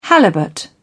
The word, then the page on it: halibut: British English